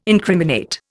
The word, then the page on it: incriminate: Indian